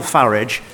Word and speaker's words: Farage: Nigel Farage and Vladimir Putin? – Yeah. Well, I'm glad he takes the English pronunciation of Farage rather than the rather poncy foreign-sounding one that he seems to prefer.